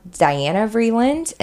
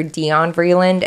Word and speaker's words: Diana Vreeland: And Dion Vreeland, which I've seen it pronounced Diana Vreeland, and we touched on her in the Met episode, but he pronounced her Dion Vreeland in the book, so I'm going to go with the way that Andre said it.